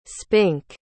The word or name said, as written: spink